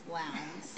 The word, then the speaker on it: Lowndes: It's like Lowndes. – Yeah, it is. I think it is pronounced Lowndes.